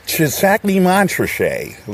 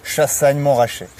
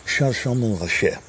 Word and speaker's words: Chassagne-Montrachet: I am in Chisagny, Montrachet, like the stupid Americans say, but of course it's pronounced Chassagne-Montrachet. – Chassagne-Montrachet.